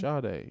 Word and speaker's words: Sade: Sade, yeah.